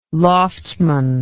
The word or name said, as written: loftsman